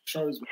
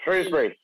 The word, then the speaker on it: Shrewsbury: It spells Shrewsbury, pronounced Shrewsbury. – Shrewsbury. – There you go.